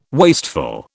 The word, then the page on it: wasteful: British English